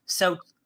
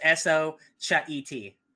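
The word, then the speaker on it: Xochitl: Apparently you pronounce it Sochiti. Because it's the S and the O are separated, so it's Sochet.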